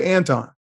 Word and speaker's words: Anton: You said Anton. But I can tell you, I've had people call me Antoine, Antonio, Antwain, all kinds of stuff. My name is five letters, two syllables, right? And I will correct you once or twice. But on the third time, if you're still calling me something other than Anton, then you're saying to me that you don't care enough about me to even pronounce my name right.